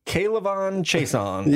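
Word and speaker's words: K'Lavon Chaisson: Kalavon Chaisson.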